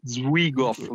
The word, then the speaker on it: Zwigoff: In cui è bellissimo perché è prodotto, anche qui vado a sbagliare, vado a sbagliare la pronuncia, da Terry Zwigoff, si dica così.